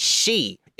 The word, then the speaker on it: Sith: It's spelled Sith, but it's pronounced like Banshee. – Oh, well, they pronounce it wrong then in Final Fantasy, don't they? – Yeah. – Because they say Kate Sith in Final Fantasy. Yeah. – There are only two Sith. – But they're probably doing that on purpose. I don't know. – So it's Kate. – Tell me of this additional Sith. – I believe it's pronounced she, but it is spelled like the Star Wars bad guys.